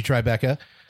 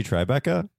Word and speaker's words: Tribeca: Angie Tribeca. – He says it weird. – Tribeca? Yeah, he puts the emphasis on the wrong syllable. – Tribeca.